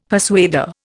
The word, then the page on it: persuader: Indian